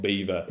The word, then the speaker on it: Belvoir: I'm living near Beaver right now. – Yeah. – And it's spelt like Belvoir.